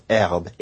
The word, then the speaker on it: herbe: So French spells herbe, H-E-R-B-E, but the H hasn't been pronounced for a very long time.